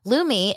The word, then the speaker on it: Lume: Lumi, it's spelled L-U-M-E.